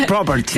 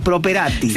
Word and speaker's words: Properati: No es Properti. – Sí, estaban preguntándonos acá. – Yo digo más italiano, Properati.